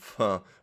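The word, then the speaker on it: phở: wird ja auch eigentlich Pho ausgesprochen. Pho.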